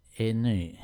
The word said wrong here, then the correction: Enya: Ja, men det uttalas Enya.